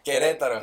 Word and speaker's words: Querétaro: How do you say it? – Querétaro. – I don't know. Who cares? – You said it right.